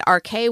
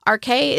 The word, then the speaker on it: Arche: My guest today is Grayson Davies, winemaker at Arche Winery in St. Joe in far, far north Texas. Arche is spelled A-R-C-H-E, but it's pronounced like the two letters R and K.